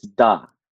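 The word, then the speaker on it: da (да): It's da.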